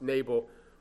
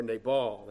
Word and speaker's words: Nabal: Let me just say at the outset, with regards to the pronunciation, this term, as you know, can be either pronounced Nabal or Nabal. And so we're going to go with the first.